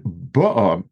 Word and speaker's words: ba'a: And that would be the emphasis on the second vowel. And then ba'a, meaning she came with the sheep, there the emphasis is on the first vowel.